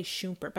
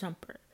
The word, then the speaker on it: Shumpert: I always want to say Shumpert, but I'm like, that can't be right. It's Shumpert. But anyway, they are married.